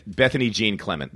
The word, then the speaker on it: Bethany Jean Clement: Bethany Jean Clement. That's fine. That's how it would be pronounced here.